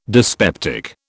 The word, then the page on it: dyspeptic: British English